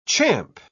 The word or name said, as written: champ